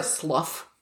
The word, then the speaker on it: slough: Where, a slough. – Yes, I want to pronounce it slough, but it's Slough.